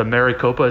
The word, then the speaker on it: Maricopa: Can I interject an update that a bunch of armed Trump supporters are gathered outside, what is it, the Maricopa, is that how you pronounce it, county? – Yeah.